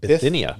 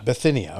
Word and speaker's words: Bithynia: Bithynia. – Bithynia, okay. – Bithynia.